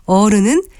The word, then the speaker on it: 어른: 그러나 선생님은 짧게 발음하는 것이 맞고 어른은 긴 어로 발음하지만 의와는 다른 발음이라는 것입니다.